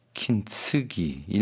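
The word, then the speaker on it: 킨츠기 (金継ぎ): 킨츠기. 일본어 발음입니다.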